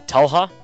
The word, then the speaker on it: Talha: I mean, everyone pronounces it differently. Like, it's actually Talha.